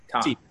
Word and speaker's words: Tock: I've never, how do you spell it? – T-O-C-K. – T-O-C-K. Tuck. – T as in tree. – Yeah, like, Tuck. Like TikTok.